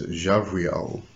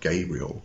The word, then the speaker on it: Gabriel: and then it's Javriel. Now, I was taught that it's not a G sound for Gabe. It's not Gabriel. It's not a hard G. It's a soft J.